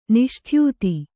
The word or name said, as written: ನಿಷ್ಠ್ಯೂತಿ